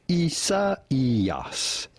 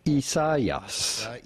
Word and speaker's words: Isaías: Because this is a name of Spanish and Portuguese origin from the biblical Isaiah, This is to be pronounced Isaías. Isaías.